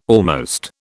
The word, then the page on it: almost: British English